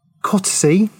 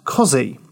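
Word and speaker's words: Costessey: In the Scouts and Schooling chapter of the audiobook iPartridge, Alan refers to the town of Cottesy. Now, I may have pronounced that wrong, but we'll get to that. Pronouncing it as it is spelt. However, anyone that's lived in Norfolk knows it's pronounced Cozzy.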